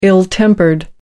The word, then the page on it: ill-tempered: American English